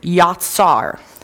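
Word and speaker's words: yatsar (יצר): The word potter here in Jeremiah in Hebrew is, I'm going to say it's pronounced yatsar.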